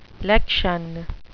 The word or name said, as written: lection